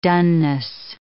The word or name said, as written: doneness